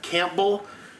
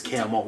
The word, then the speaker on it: Campbell: Out of town and they'll go, Campbell? And it's like, no, it's Camel. We call it, don't ask, it's Camel.